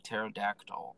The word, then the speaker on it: pterodactyl: Yeah, pterodactyl, bro. It's easy. It's tarot and then dactyl.